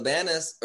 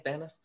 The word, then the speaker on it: Banus: camila banus or is that how you pronounce her last name i think banus mm-hmm.